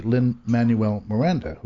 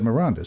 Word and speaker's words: Lin-Manuel Miranda: I always say Lin-Manuel Miranda, who wrote In the Heights. – Right. – You said Miranda, so perhaps I'm mispronouncing it.